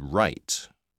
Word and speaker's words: Wright: It's W-A-L-D-H-O-F-F. – Okay, I've got that. Thank you. – 5. – My family name is Wright. That's W-R-I-G-H-T.